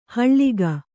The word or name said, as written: ಹಳಗೆ